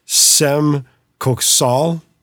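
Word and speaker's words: Cem Köksal: I believe it's Sem Koksal. It's C-E-M for his first name and K-O-K-S-A-L for his last name.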